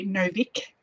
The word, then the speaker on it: Novik: Naomi Novak, Novik. I always mispronounce her name.